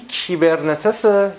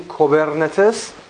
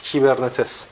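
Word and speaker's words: کیبرنتس (κυβερνήτης): یکی کیبرنتس، اگر احیانا دیدید به جای کی وای کی یو هم بود یعنی کوبرنتس، اونم اومده، ولی عموم مراجعی که قوی ترن و رفرنس های معتبرتری هستن این تلفظ رو دارن کیبرنتس.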